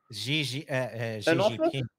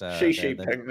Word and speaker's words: Xi Jinping: I guess the only thing that's really changed the situation is having President Ping... Xi Jinping? – Xi Jinping is how it's pronounced. – Xi Jinping, that's it, yeah.